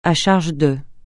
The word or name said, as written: à charge de